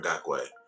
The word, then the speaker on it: Ngakoue: Yeah, Ngakoue, if that's how you say his name. – Yeah, Ngakoue.